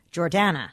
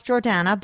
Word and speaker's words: Jordana: I say Jordana. Mm-hmm. And for some reason, some people can't pronounce that. Jordana. Like, it's Dana. Yeah. Instead of Donna or Dana.